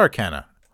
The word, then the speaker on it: arcana: So it's not arcana. Holy shit. I've been saying it wrong, I guess. It is not arcana, it is arcana.